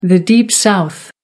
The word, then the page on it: deep south: American English